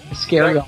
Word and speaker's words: Iscariot: Do you pronounce it Iscariot? Is that how you say it? Iscariot. Right. Yeah, I count that as an album.